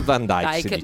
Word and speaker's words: Van Dyke: Van Dyke. Beh, insomma, noi lo pronunciamo così. – Dick Van Dyke, giustamente. Il nostro Bert ha detto giusto.